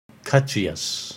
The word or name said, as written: Ksattriyas